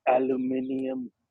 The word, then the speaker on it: aluminium: The correct pronunciation is aluminium. It's aluminium.